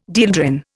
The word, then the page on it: dieldrin: Indian